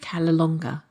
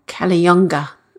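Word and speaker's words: Cala Longa: Me and my brother decided to return to this beautiful resort in Ibiza called Calalonga, or Calayonga, as the locals pronounce it.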